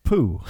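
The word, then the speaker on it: P'u: And I think the way that's actually pronounced is, I'm probably not going to pronounce it correctly, but I'm going to have a go. Poo. That's P apostrophe U.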